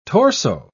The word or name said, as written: torso